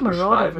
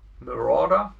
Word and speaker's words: Marauder: Ähm, ich versuche das mal so ein bisschen ich glaub, zu Marauder wird es ausgesprochen. Wie? Marauder. – Marauder, okay.